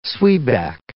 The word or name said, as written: zwieback